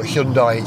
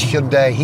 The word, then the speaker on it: Hyundai: The way that the mighty Hyundai Kia Corporation, hang on, I've got to say it right, it's Hyundai, isn't it? It's not Hyundai, it's Hyundai, even I've forgotten.